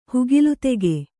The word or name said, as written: ಹುಗಿಲುತೆಗೆ